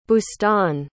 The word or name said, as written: bustan